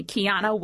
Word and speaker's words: Qiana: Kiana Williams. First name is spelled Q-I-A-N-A.